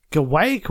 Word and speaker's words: guaiac: It's got oak extract, and it's got, the one that I can't pronounce, guaiac wood. – Guaiac wood? – Guaiac. It's G-U-A-I-A-C-W-O-O-D.